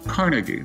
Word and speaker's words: Carnegie: Uh, the first is uh, Carnegie, the Carnegie Foundation.